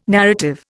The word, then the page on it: narrative: Indian